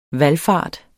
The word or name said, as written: VALFARTER